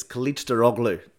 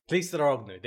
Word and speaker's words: Kılıçdaroğlu: I've spent a week learning how to do it. It's Kılıçdaroğlu. – Kılıçdaroğlu, there you go.